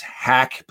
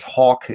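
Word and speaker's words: Haack: That is something that probably should be an onus for them, because, even a shaky snap, you have a punter, his name is Hack, but they're trying to say he pronounces it as Hawk.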